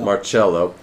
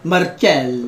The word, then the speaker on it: Marcello: Marcello. No, Marcello. – Marcello. – There you go. Okay, go ahead. When you say it, you gotta say it like that.